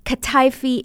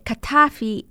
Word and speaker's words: kataifi: kataifi milfil. Just by the way, this kataifi, or I should say kataifi is how you say it in Greek. Kataif is how you say it in Arabic.